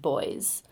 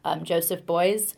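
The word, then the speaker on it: Beuys: I think it's pronounced Boys, Joseph Boys.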